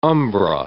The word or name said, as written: umbra